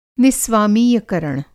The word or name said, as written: निस्वामीयकरण